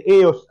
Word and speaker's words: EOS: EOS, así tal como se pronuncia, EOS.